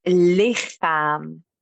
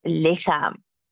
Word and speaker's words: lichaam: Het lichaam. So it's written with CH, but we pronounce it as our favorite letter, the G. Het lichaam.